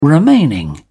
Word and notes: remaining: British English

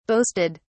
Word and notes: boasted: British English

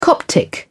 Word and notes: coptic: British English

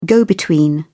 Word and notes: go-between: British English

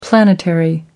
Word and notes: planetary: American English

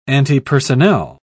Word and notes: antipersonnel: British English

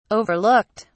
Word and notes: overlooked: British English

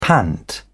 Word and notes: pant: British English

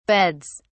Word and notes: beds: British English

